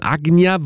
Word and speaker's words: Ajna: Ajna wird es eigentlich im Sanskrit ausgesprochen. Die meisten würden wahrscheinlich Ajna zu sagen. A-J-N-A geschrieben, ist aber gleichzeitig auch ein belgischer Produzent. A-J-N-A geschrieben, der sich hier mit Mischa zusammengetan hat.